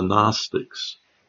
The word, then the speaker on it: Gnostics: But back in the day of the Apostle John, and actually to some extent even today, there was a group called the Gnostics. It's spelled funny, Gnos, N-O-S-T-I-C, and that means knowledge.